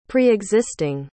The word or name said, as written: preexisting